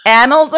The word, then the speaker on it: annals: Read the pronunciation. – Oh, okay. Hold on. – I'm going to say annals. – Annals. Annals. Yeah, now that we cleared that up.